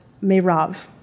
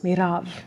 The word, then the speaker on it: Merav: Her name is pronounced Merav. It's pretty. I like that. Anyway, I should say, you have to roll your R's. Merav.